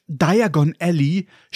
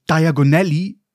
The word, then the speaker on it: Diagon Alley: Auf Englisch Diagon Alley, schnell ausgesprochen Diagonelli, diagonal.